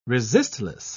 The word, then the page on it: resistless: British English